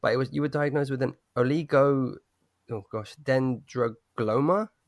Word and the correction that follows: oligodendroglioma: tumor, is that correct? – Yeah, oligodendroglioma, yeah. – Yours sounded better.